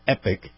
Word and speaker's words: Epoch: that's E-P-O-C-H, it's pronounced Epic.